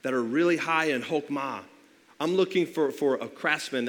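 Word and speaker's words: chokmah (חָכְמָה): And so if we were to be reading the Old Testament in the original Hebrew, we would find that this word wisdom, it's pronounced chokmah, and you can kind of see how it's written up there.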